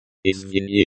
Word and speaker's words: Извини: Извини, the I in is pronounced like the I in bit. The I in V is pronounced like the E in C. The ni is pronounced like the ni in knee.